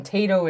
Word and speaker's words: Tayto: No, that's Irish people are mad for Tato Crisps. – Mm-hmm. Yeah, and Tato is a brand. Mm-hmm. We're not just mispronouncing the word potato. – Correct. T-A-Y-T-O.